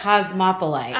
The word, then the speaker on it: cosmopolite: Everyone's always like, it's Cosmopolite. Cosmopolite. – Like Cosmopolitan, but it's Cosmopolite.